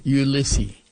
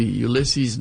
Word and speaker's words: Ulysse: Ulysses. U-L-Y-S-S-E. Ulysses, no S. It was French, right? So, no S.